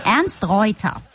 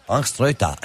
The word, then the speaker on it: Ernst Reuter: Ernst Reuter deyince. – Evet. Nein, Ernst Reuter. – Ernst Reuter, evet.